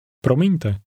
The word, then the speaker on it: Promiňte: Promiňte.